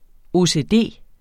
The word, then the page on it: OCD: oseˈdeˀ; ˈoˀˈseˀˈdeˀ